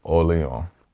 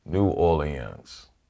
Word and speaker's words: New Orleans: If you think of the French rhythm, Orléans. – Yeah. – We have the French rhythm without the French sound. New Orleans.